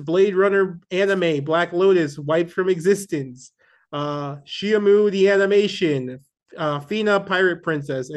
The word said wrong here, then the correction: Shenmue: Did you just pronounce it Shyamu? It's Shenmue. Shenmue.